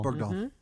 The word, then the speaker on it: Bergdahl: President Obama, remember when we traded some Guantanamo guys for that, how do you say his name, Bergdahl? – Bergdahl.